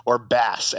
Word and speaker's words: BaaS: Yeah, so this was the coming out party for banking as a service, or BaaS, as we learned it is pronounced, like the fish.